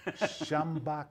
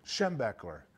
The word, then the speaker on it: Schembechler: How do you say it? Schembach. I go, come on, Dad. We're DeLamelier. Schembechler.